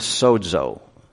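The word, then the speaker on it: sozo (σῴζω): The Greek word here is actually the word sozo. It's spelled S-O-S-O, like bozo with an S and a D in there.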